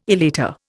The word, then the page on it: elater: Indian